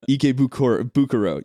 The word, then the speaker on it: Ikebukuro: Ikebukuro or whatever it is. – Ikebukuro.